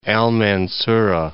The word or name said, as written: Al Mansurah